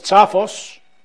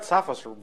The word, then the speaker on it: Tsafos: Nikos Tsafos is president and chief analyst of Enalytica. This is how I want to pronounce it. And I will pronounce it that way forever. – You got Tsafos very well.